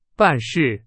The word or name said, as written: 办事